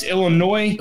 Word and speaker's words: Illinois: So it's Illinois. – Illinois. – S is silent. Just like those L's.